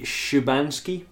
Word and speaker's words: Szubanski: Her name is Magda, and it's spelled S-Z-U-B-A-N-S-K-I. – Because she had a Polish parent. – I believe it'll be Szubanski, perhaps? – Yeah, sure. – Szubanski? Magda Szubanski.